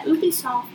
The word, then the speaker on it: Ubisoft: Yeah, Ubisoft.